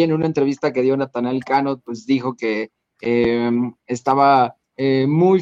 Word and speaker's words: Natanael Cano: porque estrenó su tema junto a Natanael Cano, si lo dije bien. – Sí.